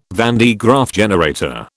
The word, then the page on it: van de Graaff generator: British English